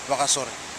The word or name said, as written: wakasore